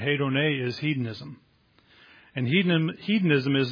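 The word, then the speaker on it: Hedone (ἡδονή): Hedone. That's not what it looks like, but that's how it's pronounced.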